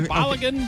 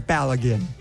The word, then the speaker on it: Balogun: But I do believe it is Balogun. Balogun.